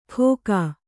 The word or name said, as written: ಘೋಕ